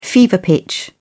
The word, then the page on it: fever pitch: British English